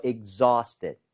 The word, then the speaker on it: exhaust: AU나 AW 스펠링은 대부분 어에 가깝게 발음이 돼요. – 네. – 아우가 아니라. – 예. – So exhausted, exhausted. – exhaust. – 이렇게 발음을 하는 게 맞습니다.